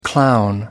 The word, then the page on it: clown: American English